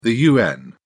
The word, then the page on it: UN: British English